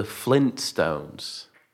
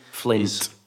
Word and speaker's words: Flintstones: Is this pronounced the Flintstones or the Flintstones? – The Flintstones with a T. The Flintstones with a T. – Flintstones. – Correct.